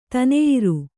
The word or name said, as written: ತನೆಯಿರು